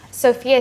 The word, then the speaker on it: Sophia: Sophia Taylor. – Okay, that's Sophia. S-O-F-I-A? – No, S-O-P-H-I-A.